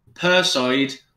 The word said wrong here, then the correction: Perseid: how do you say this? – Perseid. It's a meteor shower.